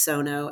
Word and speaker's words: Suno: So there is an AI tool called, I always pronounce it, I think it's Sono, S-U-N-O.